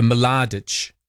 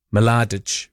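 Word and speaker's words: Mladić: And it does say, you can say Ma, or you can say, like you do with the L, you can go Mladic. Mladic. But, yeah, I'm not.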